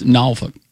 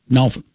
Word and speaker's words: Norfolk: And he says, yes, it sounds obscene, but just take a little of the F-U-K or the F-I-C-K, gnaw, like drawl. And then a fast. – Okay, I got you. – Yeah, you say it by itself, so it's Norfolk. – Mm-hmm. – Okay, that was very good. – Norfolk.